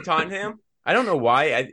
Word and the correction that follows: Tottenham: Is that like, I think I might be an American thing because I've heard a lot of Americans say that same thing, but it's Tottenham.